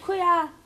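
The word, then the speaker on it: cooyah: It's spelled COO. Y-A-H. Kuya! Watch the frock. – Yes, watch the shoes. Watch our ear. Kuya.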